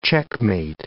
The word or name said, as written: checkmate